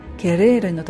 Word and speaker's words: querer: それで、今回取り上げる単語はケレールという単語です。 QUERER と書くんですけれども、ケレールのとこのレが本当は巻き舌にならなきゃいけないんで、この不定形の形は非常に発音が難しいんですね。